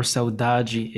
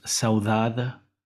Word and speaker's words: saudade: Today we're talking about the word saudade or saudade. Saudade or saudade is one of the most famous words in the Portuguese language. In Portugal, it's pronounced saudade. In Brazil, it's pronounced saudade or saudade.